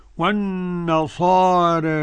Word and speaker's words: والنصارى: قرأ ورش عن نافع والنصارى بالتقليل قولا واحدا.